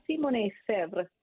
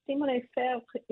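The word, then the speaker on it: Simonnet-Febvre: yes, it's hyphenated Simone, which is spelled like phonetically Simonette. And Febre is a little trickier. It has a B in there when you don't think there should be, but it's Simone Febre.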